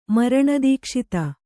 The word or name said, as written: ಮರಣದೀಕ್ಷಿತ